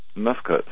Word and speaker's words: Mifkuts: Now, you speak to great length in Lost Secrets of the Sacred Ark about something that you call, I can't even pronounce it, Mifkut? – Mifkuts, yeah.